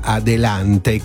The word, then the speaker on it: adelante: La pronuncia spagnola è circa adelante, con accento sulla seconda A.